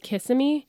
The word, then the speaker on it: Kissimmee: Is that how it's pronounced? – I don't know. – I think the correct is Kissimmee.